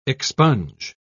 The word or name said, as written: expunge